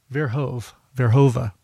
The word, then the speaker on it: Verhoeven: From a project that he put on his MySpace page, that is Wes Verhoeven, I believe it's pronounced Verhoeven, better known as Wakey Wakey. And is it Wes Verhoeven? Let me just check that really quickly. Verhoeva. There's no N.